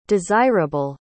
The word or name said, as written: desireable